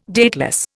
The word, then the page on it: dateless: Indian